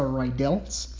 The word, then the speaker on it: Riedel: I would really hope you're thinking about a Riedel's thyroiditis under those circumstances. So Riedel is spelled R-I-E-D-E-L, Riedel's thyroiditis, Riedel's thyroiditis.